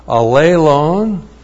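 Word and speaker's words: allelon: When it's used with the reciprocal pronoun alelon, alelon looks like that. A-L-L long E-L long O-N. Alelon.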